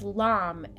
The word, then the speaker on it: Lam: Lam, L A M.